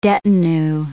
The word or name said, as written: detinue